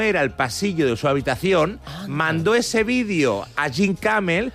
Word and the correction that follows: Jimmy Kimmel: Como los americanos tienen pasta gansa. – Jimmy Kimmel. – Bueno, tú pronuncias bien, yo lo no, hago de rollo de Menorca.